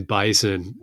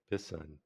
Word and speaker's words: Bisson: I was just saying bison. Yeah. Bison. I know there's usually one S, I think, in bison, right? – Bisson. Bisson, bison.